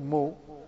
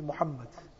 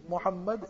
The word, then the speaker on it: Muhammad: Therefore our children also, anybody has to keep some nickname for them, tell them Now you see, his name is not Mo, his name is Muhammad. His name is Muhammad, and learn to pronounce the names correctly.